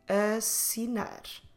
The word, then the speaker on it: assinar: Another example, the verb to sign is assinar. Assinar. Again, double S. Assinar.